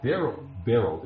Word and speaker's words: beryl: Okay, beryl. It's B-E-R-Y-L, but it's pronounced beryl.